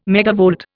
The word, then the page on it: megavolt: Indian